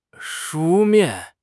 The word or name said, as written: 书面